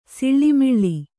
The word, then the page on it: ಸಿಳ್ಳಿಮಿಳ್ಳಿ: Kannada